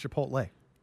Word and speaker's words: Chipotle: You said it wrong. It's Chipotle.